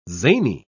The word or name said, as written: zany